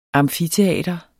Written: amfiteater

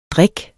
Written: drik

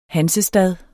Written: hansestad

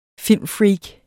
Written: filmfreak

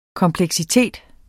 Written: kompleksitet

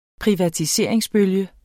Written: PRIVATISERINGER

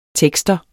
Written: tekster

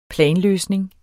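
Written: planløsning

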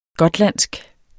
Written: gotlandsk

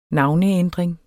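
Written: navneændringer

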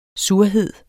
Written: surhed

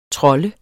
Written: trolde

